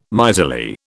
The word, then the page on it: miserly: British English